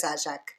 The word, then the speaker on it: Ajak: En inglés sería Ajak, porque pronuncian así, pero es Ajak, porque es griego, así que no me importa.